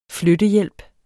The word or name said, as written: flyttehjælp